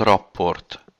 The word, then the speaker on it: Rapport: Richard Rapport.